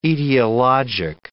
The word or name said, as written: etiologic